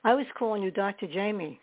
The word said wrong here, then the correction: Jaime: I didn't know that the J was silent. So forgive me. You are Dr. Jaime Romo.